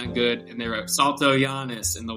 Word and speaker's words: Saltojanes: And I want to share, guys, his name is Keith, K-E-I-T-A-H, and his last name is S-A-L-T-O-J-A-N-E-S. So the Jane part that you might want, you're tempted to say Jane, it's Giannis, that's how it's pronounced, but it reads Jane.